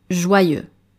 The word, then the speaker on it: joyeux: The next word, joyeux, joyeux, J-O-Y-E-U-X, joyeux. Ici, vous êtes tenté de lire joyeux, joyeux, mais il faut prononcer joyeux, joyeux comme la joie, happiness. The final X is also silent, joyeux.